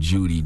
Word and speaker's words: Jeudy: I think it's extremely stupid because your last name is spelled Judy, J-E-U-D-Y.